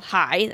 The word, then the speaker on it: Hye: So we own 70 acres that the distillery sits on itself in this little town in the hill country called High. That's spelled H-Y-E.